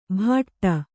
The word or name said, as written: ಭಾಟ್ಟ